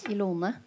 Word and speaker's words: Ilone: Ja, og den uttrykksformen denne gangen er uh, en bok der Jone er Det er sånn det uttales? Ilone. Ilone? – Ja.